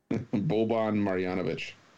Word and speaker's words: Boban Marjanovic: Boban Marjanovic.